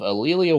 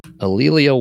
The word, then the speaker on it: A'Lelia: So Ed Wilson is the husband of Alelia Walker, the richest woman in Harlem. – Could you spell that name? – Yes. Ed Wilson, husband of. Alelia Walker.